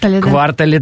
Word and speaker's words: квартале: Квартале, да. – Вообще правильно говорить квартале при любых обстоятельствах, если что, я просто дарю.